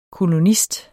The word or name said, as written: kolonist